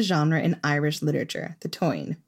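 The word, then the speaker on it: Táin: We did an episode about the cattle raid of Cooley, and throughout it, we called it the Tane, which is not the correct pronunciation as far as I can tell.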